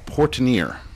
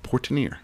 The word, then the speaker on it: Portenier: P-O-R-T-E-N-I-E-R. Portenier. – I'm sure we'll hear about it. – Portenier. – Portenier. – I don't know. – All right, whatever. – One of the two. – A French pronunciation.